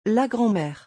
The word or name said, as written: La grand mere